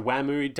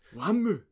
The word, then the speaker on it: Wamuu: So Whammu, W-A-M-U-U, mm-hmm. apparently it's pronounced identically to how they would say Wham in Japanese. That makes sense, I guess. – Whammu.